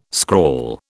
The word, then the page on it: scrawl: British English